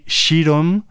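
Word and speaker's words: Širom: Se trata de un grupo que ya conocemos por aquí, Shirom. Se escribe pues bastante parecido a como se dice Sirom, terminado en M. Eso sí, la S tiene como un sombrerito del revés, por así decir.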